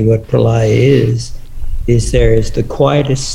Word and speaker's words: Pralaya: Pronounce it for me. – No, the yoga. – Pralaya. – And that's Pralaya. You know